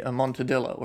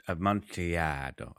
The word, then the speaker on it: Amontillado: Like the cask of the Amontadillo, or... – Amontillado. I'm pretty sure I'm saying it wrong too, but whatever.